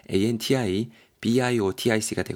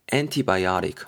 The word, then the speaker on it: antibiotic: ANTI BIOTIC가 되고요. 항생제입니다. 항생제. antibiotic 혹은 antibiotic 이렇게도 발음이 될 수 있습니다.